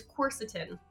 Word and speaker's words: quercetin: I'll take quercetin, but the way I pronounce it is quercetin. – Quercetin, got it. You're just putting the emphasis on a different syllable.